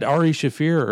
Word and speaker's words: Ari Shaffir: You said Ari Shaffir earlier. Which is how it's properly pronounced. – Oh, well, whatever. – Now you're saying Ari Shaffir.